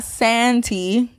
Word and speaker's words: Santi: And it's not Santi. It's pronounced like with a Spanish accent. So it's Santi.